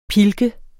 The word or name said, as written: pilket